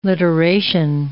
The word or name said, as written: literation